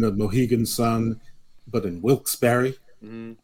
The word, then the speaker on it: Wilkes-Barre: But Monday Night Raw comes waltzing back into our lives from the Mohegan Sun Arena at the Casey Plaza in Wilkes-Barre. How do you pronounce that? – I say Wilkes-Barre, but I think it's Wilkes-Barre.